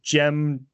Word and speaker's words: Djem: So it's called Shen and Gem. So Gem is spelled D-J-E-M. – Oh, like Jin.